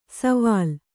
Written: ಸವಾಲ್

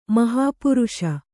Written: ಮಹಾಪುರುಷೆ

